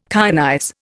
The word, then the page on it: kyanize: Indian